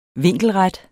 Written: vinkelret

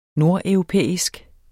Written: nordeuropæisk

